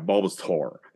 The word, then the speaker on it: Bulbasaur: Bulbasaur. Thank you. – Like dinosaur. – Yeah, Bulbasaur. There you go.